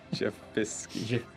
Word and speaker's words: Jephisky: So J-E-P-H-I-S-K-Y. – Riley? Jephiski? – Jephiski. – Sorry, spell that again? – J-E-P-H-I-S-K-Y.